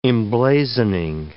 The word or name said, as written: emblazoning